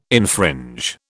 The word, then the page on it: infringe: British English